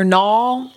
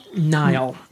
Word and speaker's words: Niall: Then you've got Niall or Nall? – Niall. – Niall? Oh my God. – I mean, that's just a name. That's how a name is. That's how the name is pronounced.